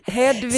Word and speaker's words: Hedvig: Att jag börjar med att uttala Hedvig. Perfekt. – Hedvig. Silent H.